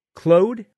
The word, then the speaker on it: Clode: The project is led by Danny Clode, C-L-O-D-E. I could not find a pronunciation. Clody? Clode? I'm going to go with Clode, I think. – Clode sounds good.